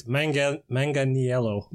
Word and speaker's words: Manganiello: Actually, I mispronounced his name. It's Manganiello, but it's Italian.